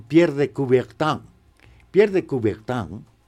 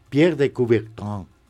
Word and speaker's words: Pierre de Coubertin: Bueno, hoy recordaremos al barón, Pierre de Coubertin, hay que hacer así como gangoso. Cuando hay una vocal y luego una N en francés, tienes que hacer A. Por ejemplo, vino se escribe B chica y N, se pronuncia van.